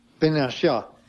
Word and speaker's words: Binoche: Binoche.